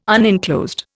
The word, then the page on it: unenclosed: Indian